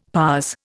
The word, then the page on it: pas: Indian